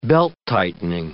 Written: belt-tightening

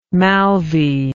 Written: moulvi

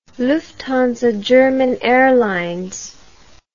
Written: Lufthansa German Airlines

